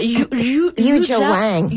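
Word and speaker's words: Yuja Wang: Yu Jia Wang.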